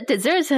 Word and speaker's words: dessert: 那 dessert 很重要的就是它的拼法和沙漠 desert 很像，但是呢，它有两个 s，不是一个，然后发音也不一样。 – That's right. Instead of desert, which is dry and perhaps painful, we have dessert, which is, of course, delicious and feels great.